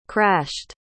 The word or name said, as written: crashed